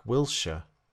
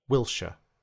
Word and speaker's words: Wilshere: These are pronounced in exactly the same way as the England player Jack Wilshere. Listen. Yorkshire, Wilshere.